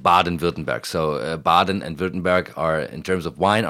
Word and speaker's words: Württemberg: It is Württemberg. It looks like Württemberg when you look at it, but it's a Württemberg. – It's Württemberg, exactly.